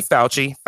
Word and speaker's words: Fauci: Number four, Anthony Fauci? I'm going to say it wrong. – You said it right.